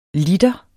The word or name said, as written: LITERNES